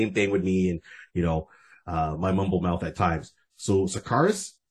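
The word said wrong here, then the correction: Skartaris: I think is the name of the world that he was in. – Scarterus. – Okay, good. Yeah, I'll go with Rod's pronunciation more over mine.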